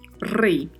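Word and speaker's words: rey: E a particularidade de pronúncia é o R, né, que é vibrato, lá I grega tem som de I. Então vamos pronunciar rei.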